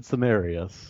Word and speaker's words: Cenarius: Or Cenarius? How do you pronounce that? – I've always said Cenarius.